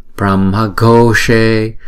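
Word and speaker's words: Brahmaghoshe: Chữ G, H này chúng ta đọc là G, G, thấy không? Âm bậc đó. Pramhagoshe. Và chữ O là hai nhịp, chữ E là hai nhịp. Chữ S là uống cong lưỡi lại và búng ra. She, she.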